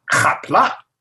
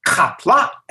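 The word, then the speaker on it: Qapla': I don't remember what it said, but it said, "Kapla." And I said, "Kapla." And then I heard my voice say, "You said ka, and you should have said ha."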